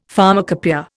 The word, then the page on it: pharmacopoeia: Indian